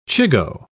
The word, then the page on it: chigoe: British English